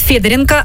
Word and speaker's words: Федоренко: И сегодня в 1 класс идет Федоренко, ну, ударение так ставит, Федоренко Арсений.